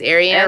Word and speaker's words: Ariane: It's just I always tell people it's just like Marianne, but you take off the M, so it's Ariane.